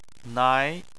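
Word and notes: nai: tone 2

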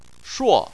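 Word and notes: shuo: tone 4

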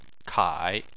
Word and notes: kai3: chinese

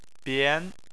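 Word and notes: bian: tone 2

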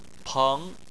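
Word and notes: peng2: tone 2

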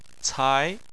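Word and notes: cai: tone 2

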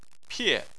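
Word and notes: pie: tone 4

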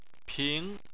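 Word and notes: ping: tone 2

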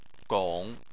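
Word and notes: gong3: tone 3

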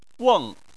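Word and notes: weng: tone 4

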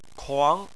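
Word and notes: kuang: tone 2

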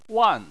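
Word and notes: wan: tone 4

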